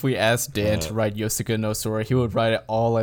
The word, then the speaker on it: Yosuga no Sora: Yosuga no Sora.